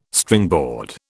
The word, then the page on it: Stringboard: British English